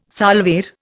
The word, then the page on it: salver: Indian